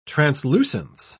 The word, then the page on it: translucence: British English